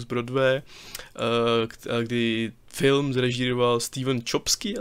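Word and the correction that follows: Chbosky: asi se to vyslovuje, eh, který má zase... Čbosky, ne?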